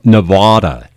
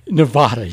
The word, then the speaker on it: Nevada: It's Nevada. – Nevada, yes.